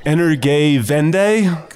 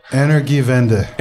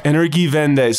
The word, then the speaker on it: Energiewende: Energiewende. – Oh. – Ooh. So how do you actually pronounce it? – Energiewende. – Energiewende.